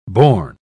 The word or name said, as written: born